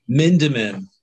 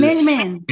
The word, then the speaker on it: Mindman: So is it Mindman or do you have an extra syllable in there? – Mindman is Mindman. Usually how we say it.